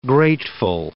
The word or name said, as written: grateful